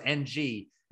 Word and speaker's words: Ng: But it's like, no, we've always stuck with someone decided it was NG. And so Detective Ng, in this case, is how it's pronounced in the movie.